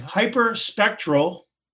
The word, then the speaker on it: hyperspectral: Orbital Sidekick, or OSK for short, is providing hyperspectral, am I right? Is that how we pronounce it, Dan? – You got it.